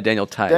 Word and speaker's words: Theis: Maybe Daniel Theis? Is that how you pronounce it? Tice? – Oh, yeah, Daniel Tice.